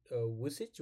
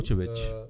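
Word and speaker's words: Vučević: Vucic? What's his? How do you say his name? – Vucevic.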